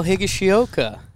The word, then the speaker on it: Higashioka: It's more like a Spanish pronunciation, so the Latin guys say it perfectly. – Oh, really? – And the American guys have trouble with it. – It's pretty funny. – Oh, damn. So what's it with the Spanish flair? – Well, like the vowel sounds are exactly the same. So like Higashioka. – Oh, not Hig. – The I's sound like E.